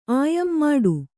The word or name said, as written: ಮುಟ್ಟ